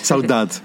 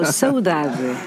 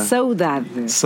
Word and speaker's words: saudade: Saudade. – Okay, I said it wrong. I'm sorry. – No, saudade. – Yeah. – Saudade. Yes, right.